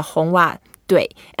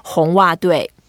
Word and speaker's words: 红袜队: You are in the 红袜队. And 队 is the team. So Red Sox is the short way of saying it. But to say it properly, we say the Red Sox team, 红袜队. – 红袜队.